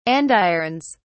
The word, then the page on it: andirons: British English